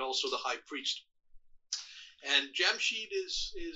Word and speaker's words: Jamshid: His name is Jamshid, or sometimes Yamshid, if the J is pronounced, is silent.